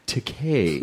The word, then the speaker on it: Takei: I was like, George Takei, I mean, and he goes, no, it's pronounced Takei. It rhymes with gay. Yeah. As in, it's okay to be Takei.